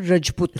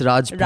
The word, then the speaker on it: Rajput: It's not Rajput. – It's Rajput.